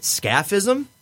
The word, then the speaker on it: scaphism: Scaphism? S-C-A-P-H-I-S-M? – Sounds right.